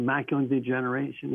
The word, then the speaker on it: macular degeneration: A lot of folks that are blind in the state of Florida went blind because of macular degeneration.